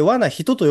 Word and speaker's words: 和な女: あ、あれあの、わな女と書いて、わな人と読むのね。